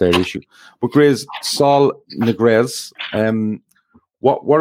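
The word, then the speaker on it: Ñíguez: Niguez.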